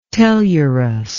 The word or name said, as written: tellurous